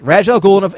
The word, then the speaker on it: Ra's al Ghul: That's how you pronounce it. Yes, Ra's al Ghul in a sword fight.